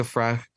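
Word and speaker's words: Fręch: Anjabur versus Magda Frech. I'll say it correctly because it bothers me to say it the other way.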